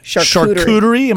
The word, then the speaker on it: charcuterie: Charcuterie. – Am I saying it correct? – You got it.